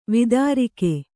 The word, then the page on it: ವಿದಾರಕ: kannada